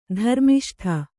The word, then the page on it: ಧರ್ಮಿಷ್ಠ: dharmiṣṭha